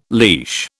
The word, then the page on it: leash: British English